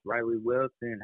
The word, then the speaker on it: Reily Wilson: Reily Wilson.